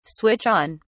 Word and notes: switch on: British English

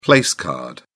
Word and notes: place card: British English

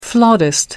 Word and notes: flautist: American English

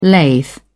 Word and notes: lathe: British English

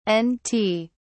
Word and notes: n't: British English

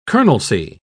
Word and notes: colonelcy: British English

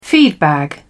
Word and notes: feedbag: British English

